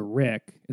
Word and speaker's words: Riq: So I've been bringing this Egyptian tambourine that I play called the Rik. It's spelled R-I-Q.